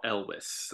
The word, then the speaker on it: Elwes: And it's like, how do you say his name? Carrie Elwes. – Yeah, I always said Elwes.